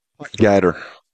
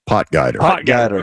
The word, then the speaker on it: Potgieter: Is it Aldrich Potgieter? – Potgieter. – One more time? – Potgieter. – Okay.